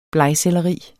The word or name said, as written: BLEGSELLERI